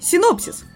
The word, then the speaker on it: синопсис: Ну и я приняла окончательное решение говорить синопсис, потому что это слово, оказывается, пришло с английского. И в оригинале ударение идет на О, поэтому буду говорить на оригинал.